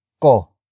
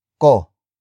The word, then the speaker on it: ko: And finally, we have ko. Ko.